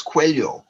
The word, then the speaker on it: Coelho: She said, say that name again, and I said it again, and she laughed and says, no, it's Coelho.